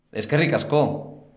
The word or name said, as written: eskerrik asko